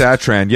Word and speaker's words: Rory Satran: Rory Satran.